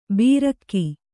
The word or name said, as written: ಬೀರಕ್ಕಿ